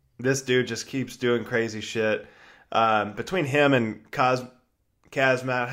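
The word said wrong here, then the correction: Khamzat Chimaev: Kamzat Chimaev.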